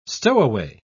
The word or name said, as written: stowaway